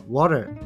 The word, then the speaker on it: water: 正しい発音は water なんですよねだからカタカナに直すと「わら」みたいに聞こえてあのー、これ発音とセットで覚えないと英会話では全く意味がないんですよね。